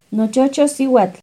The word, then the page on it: nochocho cihuatl: Náhuatl